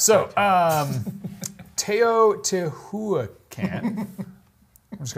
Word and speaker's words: Teotihuacan: Teotihuacan. Yeah, that's how you pronounce it. Teotihuacan, age, size, and complexity can make it seem otherworldly, but it's very much the work of humans.